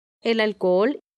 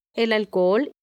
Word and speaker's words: alcohol: El alcohol. – Note, the H in alcohol is silent. Repítelo dos veces. – El alcohol. El alcohol.